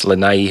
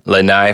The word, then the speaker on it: Lanai: It's Lanai, Hawaii. Lanai for the people who don't live on the island and don't know how to pronounce it.